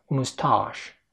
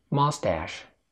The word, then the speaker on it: mustache: Mustache نطقها البريطاني كده. Mustache أو mustache. يا إما تقول مو أو ما، ولكن في النهاية هتقول تا آ تاش مستاش، ولكن في الأمريكي ماستاش ماستاش، البريطاني مستاش.